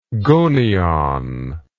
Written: gonion

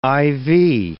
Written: IV

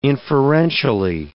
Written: inferentially

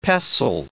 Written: pestle

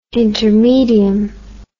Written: intermedium

